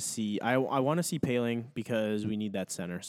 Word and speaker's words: Poehling: I'm very excited to see what Ryan Poehling, is that how you say it, Poehling? – Yeah, it's Poehling, yeah.